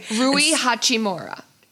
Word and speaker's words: Rui Hachimura: Now, my wow, husband, that was a great pronunciation. I'm proud of you. – I've been practicing. CJ yesterday was like, you need to practice all day. – Rui Hachimura.